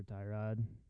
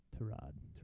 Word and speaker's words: Tyrod: I don't know, but I mean, it's definitely a shame for Tyrod, or his name's actually pronounced Tyrod.